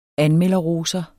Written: anmelderroser

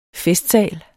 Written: FESTSALS

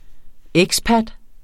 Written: expat

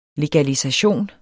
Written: legalisation

